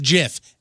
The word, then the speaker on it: GIF: It's a soft G. Wow. Pronounced GIF.